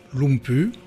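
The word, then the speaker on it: Lumbye: composée par le compositeur danois Hans Christian Lumpy. Ça s'écrit Lumbay, ça paraît que ça se prononce Lumpy.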